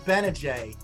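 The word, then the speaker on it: Gbinije: And today we've got a great guest, former Syracuse basketball player Michael Benajay. Silent G.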